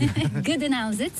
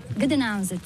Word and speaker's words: Gdańsk: Reprononce-le dans ce Gdańsk, c'est le nom slave. Gdańsk. – Gdańsk, que nous, Français, prononçons en disant Danzig.